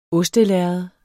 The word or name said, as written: ostelærred